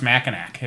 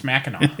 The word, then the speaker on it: Mackinac: Just because Mackinac Island has a C at the end doesn't mean it's pronounced Mackinac. It's Mackinac. The C is silent.